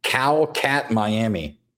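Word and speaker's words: Kotkaniemi: When somebody phonetically told Brad on the feed how to say that, they spelled Cow Cat Miami.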